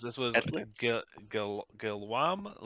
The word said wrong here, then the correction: Guillaume: Leonard. No idea if I did that correctly, but I believe it's Guillaume.